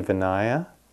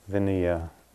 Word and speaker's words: Vinaya: And if Vinaya had been spelled with a long accent over the A, it would be Vinaya. But it's not. So the A is kind of Vinaya.